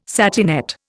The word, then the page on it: satinette: Indian